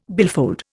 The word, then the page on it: billfold: Indian